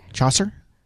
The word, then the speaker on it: Chaucer: Chaucer.